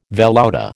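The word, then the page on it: velouté: American English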